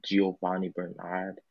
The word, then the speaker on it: Giovanni Bernard: Yeah, the man with the mysterious mustache, Giovanni Bernard. That's how you pronounce his name, right? Giovanni. – Yes. Yes. Yeah. Yes.